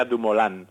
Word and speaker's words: Dumoulin: El otro día, a mí me sale Dumoulin siempre, pero el otro día cuando estaban en Holanda, que se supone que allí lo pronunciarán bien, ¿no? – Sí, se supone. – Lo dijeron, le oí al speaker que decía Dumoulin.